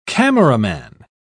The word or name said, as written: cameraman